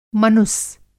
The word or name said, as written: मनुस